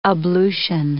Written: ablution

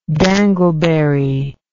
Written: dangleberry

